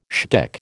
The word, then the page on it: shtick: American English